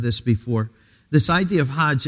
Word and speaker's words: Hajj: That's how they pronounce it, Hajj.